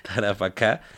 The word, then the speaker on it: Tarapacá: Tara Tarapacá. Tarapacá.